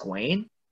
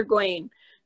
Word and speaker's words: Gawain: Gawain, actually. – It's pronounced Gawain? – Yeah, Sir Gawain.